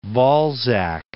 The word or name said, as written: Balzac